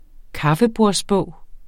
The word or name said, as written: kaffebordsbog